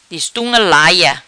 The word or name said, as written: Destunga laia